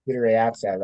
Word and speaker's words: Kudryavtsev: Kudryavtsev or whatever? – Kudryavtsev, that sounds right.